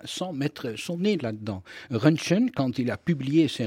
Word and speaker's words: Röntgen: Vous avez prononcé Röntgen, on va dire Röntgen, c'est comme ça qu'on le prononce en français, pour que qu'on nous comprenne.